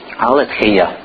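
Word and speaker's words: Алетхия (ἀλήθεια): Алетхия. Можете ударение себе поставить. Еще можно долготу показать. Вот это долгое «э».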